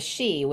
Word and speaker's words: sidhe: One of the things was, I guess you said you saw the she, which is spelled S-I-D-H-E, right? – Yes.